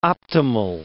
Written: optimal

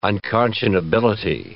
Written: unconscionability

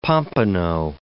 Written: pompano

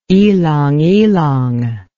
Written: ylang ylang

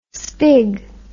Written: spig

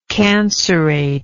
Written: cancerate

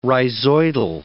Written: rhizoidal

